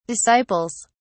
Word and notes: disciples: British English

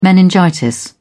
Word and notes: meningitis: British English

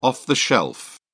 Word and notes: off-the-shelf: British English

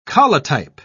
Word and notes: collotype: British English

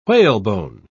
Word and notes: whalebone: British English

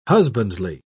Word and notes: husbandly: British English

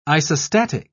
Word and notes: isostatic: British English

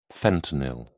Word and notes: fentanyl: British English

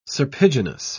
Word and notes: serpiginous: British English

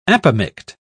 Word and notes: apomict: British English

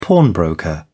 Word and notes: pawnbroker: British English

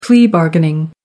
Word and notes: plea bargaining: British English

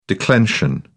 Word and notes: declension: British English

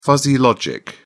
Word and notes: fuzzy logic: British English

